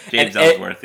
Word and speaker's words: Ellsworth: James Ellsworth, is that how you say it? – James Ellsworth.